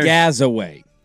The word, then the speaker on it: Gazaway: Now, I got to make sure I'm saying this right, because he told me it's Gaz like jazz, but then there's... – Gazaway.